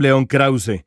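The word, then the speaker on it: León Krauze: I'm Leon Krause. I'm a journalist at Univision based in Los Angeles, California. That's the way it should be pronounced.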